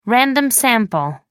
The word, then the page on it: random sample: American English